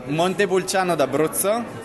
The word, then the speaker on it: Montepulciano d'Abruzzo: Montepulciano d'Abruzzo.